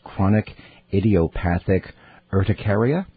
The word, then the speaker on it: chronic idiopathic urticaria: It's chronic idiopathic urticaria.